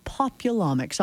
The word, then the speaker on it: populomics: Sounds like the first time I've ever said populomics. Is that right? – It's intended to rhyme with genomics. – Ah, populomics.